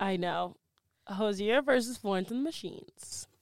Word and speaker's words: Hozier: I will go straight for Mr. I never can say his name. Hozier. – That's how I pronounce it. – Hozier.